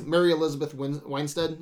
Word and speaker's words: Mary Elizabeth Winstead: Mary Elizabeth Winstead, is that how you say her name, as Huntress. – Yeah.